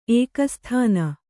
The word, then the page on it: ಏಕಸ್ಥಾನಿ: ēkasthāni